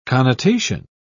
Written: connotation

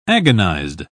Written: agonized

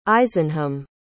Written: isenham

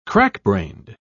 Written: crackbrained

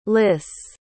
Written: liss

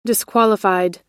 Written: disqualified

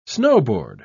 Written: snowboard

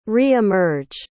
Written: re-emerge

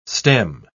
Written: stem

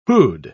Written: who'd

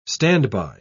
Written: standby

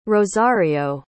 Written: rosario

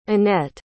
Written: annette

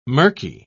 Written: murky

